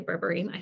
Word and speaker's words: berberine: I can never say Berberine. I should have seen that one coming. Everything You Didn't Know. Did I say that wrong, Dr. Jen? – You said it correctly.